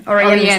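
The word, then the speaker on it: Orianne: Ja, und es geht um die Scheidung von Phil Collins und seiner Oriana, ich weiß es nicht. – Oriana spricht man sie aus.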